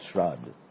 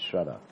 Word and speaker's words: shraddha: Now the shrad, you call it shrad, but in Sanskrit it's shraddha. That's the correct pronunciation.